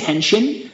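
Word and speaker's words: henshin (変身): It's like hentai, right? – And I think, or maybe I'm pronouncing it wrong. – No, say it again. – I think it's henshin. – Henshin, yes. Henshin sounds right.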